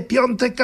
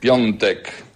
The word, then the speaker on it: Piątek: Allora amici italiani, vogliamo ripassare? – A więc włoscy przyjaciele, powtarzamy? – Piątek, piątek.